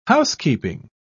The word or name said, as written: housekeeping